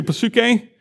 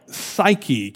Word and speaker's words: psyche: You want to say psuche? Psuche. It sounds very different in Greek when you pronounce it with the P, but in English, we use this word all the time, but we leave the P silent. It's still written there, but it's in words like psyche.